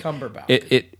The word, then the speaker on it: Cumberbatch: It's Cumberbatch. – It's Brandon Cumberbottom. – It's not Cumberbottom. I think you mispronounced it too.